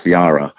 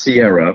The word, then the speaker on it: Ciara: I do change it, not to burst their bubble, but I tried to fight because the way this is written, the pronunciation is Ciara. However, I was beaten over the head by Christian Alfonso and a few other people that it is Ciara, which would be S-I-E-R-R-A. But I tried to fight it. I tried to fight it. – Technically, the way Ciara's name is spelled, it should be Ciara with a C-H because it's Italian.